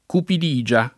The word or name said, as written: cupidigia